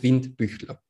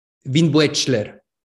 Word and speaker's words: Windbüchler: Und Wind Büchler spricht man jetzt einfach, wenn man es auf Spanisch so aussprechen würde, so wie man es schreibt, wenn man es einfach so in, wie es in Spanien aussprechen würde, wäre Winbuechler, was natürlich furchtbar ist. Also wirklich komplett furchtbar.